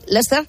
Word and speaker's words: Leicester: Hay que aclarar que esa zona, esa plaza, Leicester, se escribe Leicester, pero aquí lo dicen así, Leicester, está llena de discotecas, clubes nocturnos, así que parece que tendría más altercado callejero que otro componente.